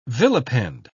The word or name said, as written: vilipend